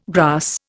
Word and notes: grass: Indian

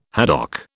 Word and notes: haddock: British English

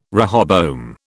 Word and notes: rehoboam: British English

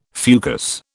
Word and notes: fucus: British English

